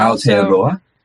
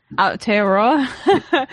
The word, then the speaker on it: Aotearoa: Researcher at Economic and Social Research, Aotearoa, if I'm saying that correctly. – And Aotearoa. – Aotearoa. Yeah, and also a lecturer of sociology at University of Otago in New Zealand.